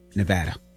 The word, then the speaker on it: Nevada: People always say that's amazing, but how does that help Nevada? Did I say it right? – Yeah, you said it right.